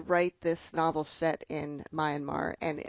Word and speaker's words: Myanmar: I usually say Myanmar. – Myanmar, okay. – it's people who are from there say kind of Myanmar, so okay. They don't quite pronounce the R at the end, but that's how I pronounce it.